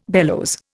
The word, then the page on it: bellows: Indian